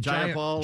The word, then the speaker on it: Jayapal: And then this girl, Representative Primila Jayapal, is that how they pronounce it? – Jayapal.